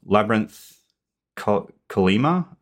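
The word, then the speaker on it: Labyrinth Chollima: But it was talking about Labyrinth Kalima, I think it's pronounced.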